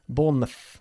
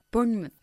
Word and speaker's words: Bournemouth: Cuando una ciudad en Inglaterra acaba con mouth, nosotros lo pronunciamos mouth, mouth, Bournemouth, ¿ok? Plymouth. – Bournemouth.